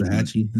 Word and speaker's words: Waxahachie: Oh yeah, Oh yeah, I I guess I've never known that I was spelling or pronouncing Waxahachie incorrectly. I say, I mean, I'm in Dallas all the time, and I always say it like that. Um, I mean, maybe Hatchie is what you're referring to. I also call it that as well sometimes. Waxahachie, and that's how you say yeah.